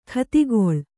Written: ಗತಿಗೊಳ್

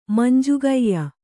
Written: ಮಂಜುಗೈಯ

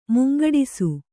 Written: ಮುಂಗೆಡಿಸು

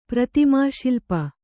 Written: ಪ್ರತಿಮಾಶಿಲ್ಪ

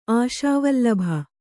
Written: ಅಸುವಲ್ಲಭೆ

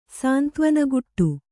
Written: ಸಾಂತ್ವನಗುಟ್ಟು

